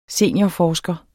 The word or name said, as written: seniorforsker